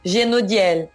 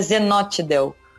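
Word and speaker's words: Zhenotdel: Uma das instituições políticas mais importantes da União Soviética, criada logo em seguida à própria Revolução, é o chamado Genodiel, né? ou, que, ou às vezes no Brasil se pronuncia Zenotdel, mas a pronúncia correta é com J, é, para a, a transliteração, né?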